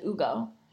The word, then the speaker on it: Hugo: So it looks like Hugo, but you pronounce it as Ugo. – Yeah, silent H.